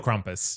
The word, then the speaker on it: Krampus: Is it Krampus? – Krampus mm-hmm. or Krumpus? – Krumpus? – In America, it's Krampus. Krampus, yeah.